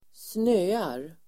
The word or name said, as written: snöar